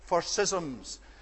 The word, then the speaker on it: schism: He is appealing to them because the word that he uses for divisions is the word for schisms. And the correct pronunciation is schism, if I may say so.